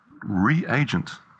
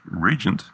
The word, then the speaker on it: reagent: It is pronounced reagent. Because it is a reagent. If it was pronounced regent, it would be spelled R-E-G-E-N-T. Reagent and regent are two different words.